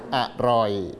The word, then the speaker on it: อร่อย: อันนี้ออกอ่ะเต็มเสียงไหมไม่เต็มออกอ่ะเพียงแค่กึ่งเสียงอร่อย